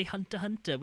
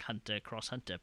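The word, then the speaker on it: Hunter × Hunter: I mean, like, the guy who made Yu Yu Hakusho is the Hunter guy, which apparently apparently you don't pronounce the cross, you just say Hunter Hunter, which is ridiculous. – Right. – That's confusing. I just opened up the Wikipedia page to get the guy's name, and it literally says at the front, it's like Hunter Cross Hunter, pronounced Hunter Hunter.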